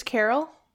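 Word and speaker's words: Carroll: Car? Roll? No, it's not Tom's Christmas car roll, Carol.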